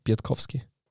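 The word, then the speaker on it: Piątkowski: Übrigens, ich habe einen Salzburg-Pressemitarbeiter gefragt, wie spricht man denn jetzt aus? Ähm, und er sagt, na genauso wie du es gesagt hast, Biertkowski. Also nicht Biertkowski oder Biertschowski oder ja, so wurde es mir von Salzburg-Seiten gesagt.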